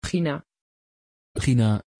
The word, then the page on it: Gina: Dutch